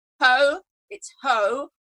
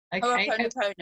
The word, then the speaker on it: Ho'oponopono: Ho'oponopono. – Okay. – Ho'oponopono.